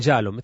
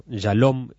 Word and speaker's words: Yalom: El autor que más ha trabajado este tema, para los oyentes que les gusta que recomendemos alguna obra literaria, es Irving Jalom, este gran psicoterapeuta norteamericano. Jalom, se escribe Y-A-L-O-M, que tiene un libro que se llama Mirar al Sol, que es cómo superar el miedo a la muerte.